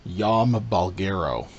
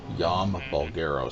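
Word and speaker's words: Jaume Balagueró: His name, it's pronounced Yom Balgero. Yom Balgero.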